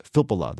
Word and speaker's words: Filppula: I always have trouble saying that name. Filppula, that's right.